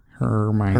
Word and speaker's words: Hermione: That would be incorrect. It is Hermione. – Hermione. – By the end of this game, you will be able to pronounce it.